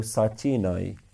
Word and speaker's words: sachi nai: So the word nai, which means greatness, is a feminine word. So sachi nai. So that's how you pronounce this.